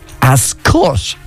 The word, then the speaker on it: Ascot: have been pronouncing Ascot wrong. It turns out, for those in the know, they tell us it should be like Ascot with a U instead of an O.